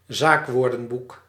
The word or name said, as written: zaakwoordenboek